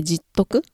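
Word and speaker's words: 十徳: 実徳、香りに 10 に徳と書いて実徳って読むらしいんですけれども、これを聞いたことありますでしょうかはい。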